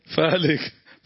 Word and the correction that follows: فارك: فارك مو فالك فارك